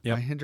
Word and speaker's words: Mahindra: Is it Mahindra? – Yep. – Mahindra Airspace.